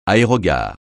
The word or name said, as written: aérogare